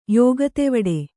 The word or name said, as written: ಯೋಗತೆವಡೆ